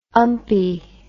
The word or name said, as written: umpy